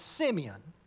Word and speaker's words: Simeon: I was reminded about this story of, I call him Simeon. You may call him Simon. It's spelled S-I-M-E-O-N in the scripture, and my southern heritage says it's Simeon.